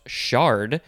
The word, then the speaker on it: chard: This one actually would be pronounced shard, even though it's spelled with a C-H.